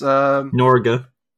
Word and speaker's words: Norge: Norge. – House. – Well, that is not the joke. That's just how it's pronounced.